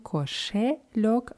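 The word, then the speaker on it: кошелёк: Kaschilok ist eine Geldbörse oder ein Portemonnaie wird mit je geschrieben Koschelok, aber das wird eher wie mit Ü ausgesprochen.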